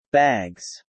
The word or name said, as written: bags